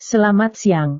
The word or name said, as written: selamat siang